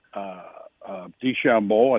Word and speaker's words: DeChambeau: That said, DeChambeau, I think that's how you say it.